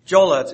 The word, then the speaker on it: Giola: And the Italians call it Jola. I'm not very good at Italian. I have to have Tony help me out. Jola, it's pronounced G-I-O-L-A, or spelled G-I-O-L-A.